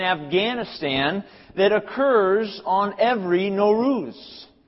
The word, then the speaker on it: Nowruz: Nowruz. Everyone say Nowruz.